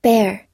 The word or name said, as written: bare